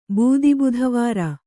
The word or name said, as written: ಬೂದಿಬುಧವಾರ